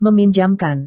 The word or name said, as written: meminjamkan